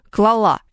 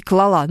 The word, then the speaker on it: клала: Ну, потому что я очень часто слышу женскую форму «клала». Это неправильно. Вам кажется, да, что, пожалуйста, там «сорвала», не знаю... поняла, поэтому и клала. Но нет, вот это как раз слово то самое, которое требует ударения на другой слог.